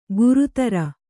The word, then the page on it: ಗುರುತರ: gurutara